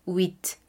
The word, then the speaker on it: huit: En el número 8 tenemos h, u, i, t, es decir, que esa h no la pronunciaremos y es wit.